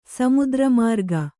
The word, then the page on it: ಸಮುದ್ರಮಾರ್ಗ: Kannada